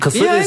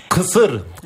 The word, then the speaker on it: Kısır: Kısır ist Kısır. Kısır. Ich kann das, da gibt es keine Übersetzung. Es müsst ihr aussprechen ohne das I bei Kısır.